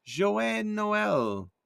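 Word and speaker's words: Joyeux Noël: Joyeux Noel.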